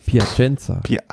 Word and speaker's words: Piacenza: Von Plaisant, nee, wie spricht man das aus? – Piacenza. – Aber dann ist es falsch geschrieben.